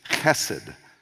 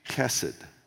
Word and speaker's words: chesed: It's actually a word, it's sort of a hard H. It's chesed, sort of a C-H, hard C-H, chesed.